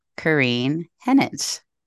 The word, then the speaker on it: Kerryne Henich: And today I am joined by special guest, Kerryne Henich.